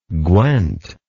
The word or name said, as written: gwent